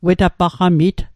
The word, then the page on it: wetapaqamit: Mi'gmaq